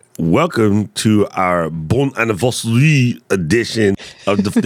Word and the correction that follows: bon anniversaire: Did I say that right? Was that Canadian? You're close. Okay. – Well, how do you say it? I don't like being close. I like being right. – Bon Anniversaire. – Oh, Bon Anniversaire. You got to say it with that proper Quebecian, French, Canadian.